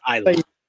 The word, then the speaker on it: Isla: Because that's no, it's Isla. The S is silent.